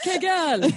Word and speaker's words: Kegel: I say Kegel. – Let's go with Kegel. Kegel. – Yeah. It's pronounced Kegel.